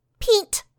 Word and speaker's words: peent: is a short, buzzy sound known as peent. – No. – It's spelled P-E-E-N-T.